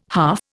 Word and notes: half: Indian

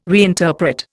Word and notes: reinterpret: Indian

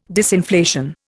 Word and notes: disinflation: Indian

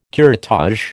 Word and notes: curettage: American English

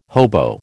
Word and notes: hobo: American English

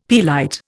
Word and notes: pearlite: Indian

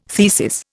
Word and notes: thesis: Indian

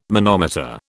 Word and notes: manometer: British English

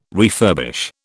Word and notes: refurbish: British English

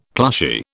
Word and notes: plushy: British English

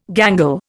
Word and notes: gangle: Indian

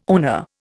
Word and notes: owner: Indian

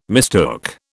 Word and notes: mistook: British English

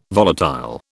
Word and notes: volatile: British English

